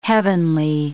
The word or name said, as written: heavenly